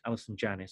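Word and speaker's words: Allison Janney: But just before we move on to George, I just want to mention, I think Alison Jane, I think it's pronounced Alison Janney, so I'll just use that.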